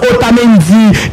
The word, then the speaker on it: Otamendi: Pour qui ça me dit Nicolas, c'est parce que en espagnol toutes les lettres prononcées, c'est ça qui me dit Nicolas Otamendi.